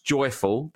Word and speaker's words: JYFL: So I thought my friend Jesse's new small business, JYFL, which is pronounced joyful, but spelled JYFL, might be of interest to him and also all the listeners who are sober or pregnant.